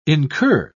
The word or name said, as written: incur